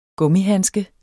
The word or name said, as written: gummihandske